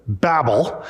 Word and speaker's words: Babel: And today we're going to look at the story of Babel. And some people pronounce it Babel, however you want to pronounce it.